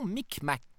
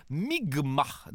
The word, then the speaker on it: Mi'kmaq: où Jacques Cartier et son équipage vont être accueillis par des membres de la nation Micmac, qui se prononce en fait Mi'kmaq, dans ce qui sera le premier contact.